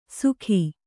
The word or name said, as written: ಸುಗಿ